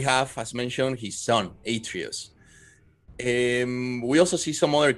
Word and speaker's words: Atreus: Atreus.